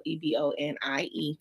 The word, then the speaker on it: Ebonie: Y'all don't forget my name is spelled Ebonie, because if you go Ebony or Ebony, baby, you're not going to find me.